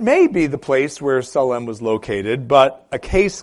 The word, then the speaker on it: Salem: I intentionally pronounce it Salem because that's how the Hebrew would have sounds.